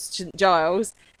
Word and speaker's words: St. Giles: But it looks like it was filmed in Chalfont, St. Giles, Buckinghamshire. – I don't know how Chalfont should be pronounced. I'm going to guess you're right there, but it's Giles, St. Giles, in Buckinghamshire.